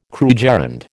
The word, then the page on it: krugerrand: American English